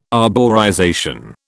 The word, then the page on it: arborization: British English